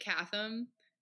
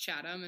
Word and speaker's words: Chatham: I kept calling it Chatham, which was funny because it's pronounced Chatham, and for some reason my brain could not compute.